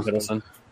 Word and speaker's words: Hiddleston: But the actor that plays Loki, was it Hiddleston? How do you say his name? – Tom Hiddleston.